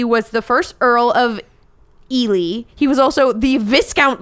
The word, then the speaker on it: Ely: Ely, E-L-Y. – Sure. – I looked up the pronunciation. Eli. I think it's Ely, like L-E-E, not E-L-I. – Okay. – But it's spelled E-L-Y.